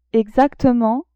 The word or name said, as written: exactement